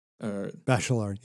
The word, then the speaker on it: Bachelard: And also, how should I pronounce his name? Is it Bachelard? – Bachelard, yeah. – Bachelard, okay.